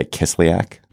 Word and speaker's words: Kislyak: Sergei Kislyak. Is that how you say it? I think you did just fine.